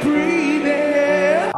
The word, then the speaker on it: breathing: Breathing. Primera sílaba ahora acentuada.